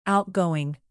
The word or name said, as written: outgoing